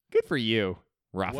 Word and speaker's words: Rafe: I have one with Luke, and it's just to say that Luke is played by Rafe Spall. I don't know if I'm pronouncing that correctly, who is Noel from Rafe.